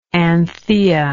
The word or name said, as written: Anthea